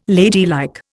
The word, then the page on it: ladylike: Indian